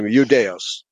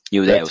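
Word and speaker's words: Eudaios (Ἰουδαῖος): Yeah, well, the pronunciation is exactly the same because the I is pronounced like a Y, okay? So Latin Judeus, it's a D-A-E, U, and the Greek is D-A-I-O, but it's pronounced exactly the same, Eudaios, okay? – Eudaios, yeah.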